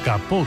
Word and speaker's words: capot: El alemán tomó prestado ese kaputt en el siglo XVII del francés capot, hoy pronunciado capó concretamente,